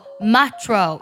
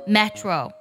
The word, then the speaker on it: macho: 它拼作 M A C H O，可以读作 Metro，也可以读作 Metro。